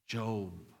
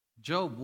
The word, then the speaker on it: Job: Then he tells us, look at Job. Who is Job? I know it reads Job, right? The first time that I was reading the Bible before, I thought it was Job. Because it is spelled Job, right?